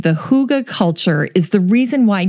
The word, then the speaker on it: hygge: I hope so, because today's show is all about hygge. It's spelled H-Y-G-G-E, so it looks like you should pronounce it hygge, but the Danes pronounce it hygge,